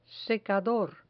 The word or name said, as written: secador